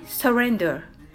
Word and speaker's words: surrender: 行でもスマートに席を譲りますよと言いたいところです席を譲るという表現ご存知ですかこれは I surrender と言います I surrender はカタカナ読みでは I surrender と言いますしかしレのところの R の発音は巻き舌をします巻いた舌先は上顎にはつけません